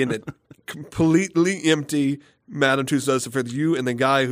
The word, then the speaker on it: Tussauds: It's Tussauds. It's if you. There's a pronunciation guide, and it's Madame Tussauds of Las Vegas. Yes, for some reason it was of Las Vegas, but the version we found. But it is Tussauds.